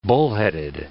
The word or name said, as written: bullheaded